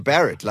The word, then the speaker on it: Barret: I don't know how people can butcher Barrett. Like, how does that get butchered? – It's interesting. They call it Beret. – Beret. – Beret. They're a bit confused of where their, you know, inflictions are.